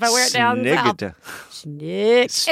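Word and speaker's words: snyggt: Yeah, just, schnooked.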